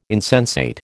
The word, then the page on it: insensate: American English